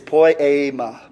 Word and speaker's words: poiema: And it says here, we are his workmanship, which in Greek is poiema. Poiema. It sounds like the word poem when you think of it.